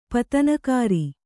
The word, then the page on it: ಪತನಕಾರಿ: kannada